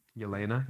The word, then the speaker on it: Yelena: Unquote Elena, how do you say her name? – Yelena. Yeah, she's spoken to Yelena.